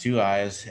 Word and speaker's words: 23Y35: It's called Two Eyes, spelled 23Y35. It's pronounced Two Eyes.